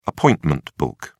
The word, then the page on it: appointment book: British English